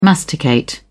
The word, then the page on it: masticate: British English